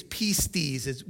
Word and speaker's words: pistis: In Greek, the word faith is pistis. We spell it P-I-S-T-I-S.